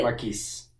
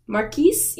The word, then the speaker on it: Marquis: And then we have the Marquess, or the Marquis, or the Marquis. I don't know how you say it. – Marquis. – Marquis? Yeah, that's what it is.